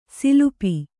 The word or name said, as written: ಸಲಪು